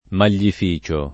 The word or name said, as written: maglificio